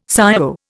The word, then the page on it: ciao: Indian